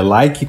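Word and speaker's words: Likee: Até porque, já nessa novela toda, a gente vai falar também do like. Eu acho que é assim que pronuncia. É. é like com um E a mais no final. Like. – É isso aí, Like.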